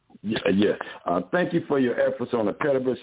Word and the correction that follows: Pettus: Bridge. Did I say that right? – Edmund Pettus Bridge, yes, sir.